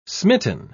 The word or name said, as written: smitten